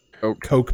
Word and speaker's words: Koch: Their name is spelled K-O-C-H. Commonly, I always pronounced it Koch Media. You thought it was Koch Media. Apparently, it is actually pronounced Koch, as in cough, cough, which is super weird.